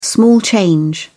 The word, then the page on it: small change: British English